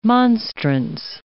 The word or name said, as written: monstrance